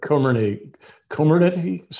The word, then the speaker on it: Comirnaty: Comirnaty? Is that how you say it? – That's how I say it. – Does anybody know? – I've heard Comirnaty, something that I call it.